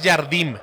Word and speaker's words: Jardim: Este sí es Jardim, con M. Se escribe como Jardín, pero sin la N, con una M.